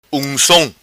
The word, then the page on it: Uncom: Macanese Patuá